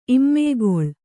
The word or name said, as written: ಇಮ್ಮೆಯ್ಗೊಳ್